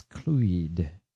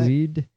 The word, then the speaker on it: Clwyd: I think it's Clwyd. – What? – Clwyd. – Clwyd. – I forget Welsh pronunciation. – I'm from Clwyd. – Where W's are vowels.